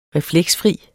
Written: refleksfri

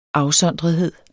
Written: afsondrethed

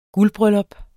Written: GULDBRYLLUP

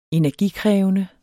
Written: energikrævende

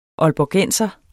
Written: aalborgenser

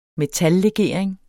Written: metallegering

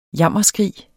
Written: jammerskrig

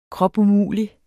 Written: kropumulig

